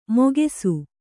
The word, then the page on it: ಮೊಗೆಸು: mogesu